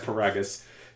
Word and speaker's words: Paragus: And his father, Paragus, however you pronounce his name. Yeah, it's Paragus.